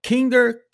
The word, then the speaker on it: Kinder: Tanto que as premissas do partido nazi eram baseadas no slogan do ex-imperador da Alemanha, Guilherme II, que ele dizia Kinder, Kirche, Kirche. Acertei a pronúncia, Fabi? – Acertou, isso mesmo.